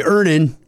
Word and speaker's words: Earnin: I'm going to pronounce this properly. Earnin. – Earnin. – E-A-R-N-I-N is pronounced Earnin. I don't know how else you would pronounce it, but they really want it clear that that's how to pronounce it.